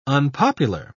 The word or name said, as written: unpopular